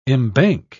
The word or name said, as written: embank